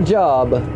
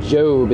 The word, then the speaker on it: Job: Not Job, Job. It's a long O.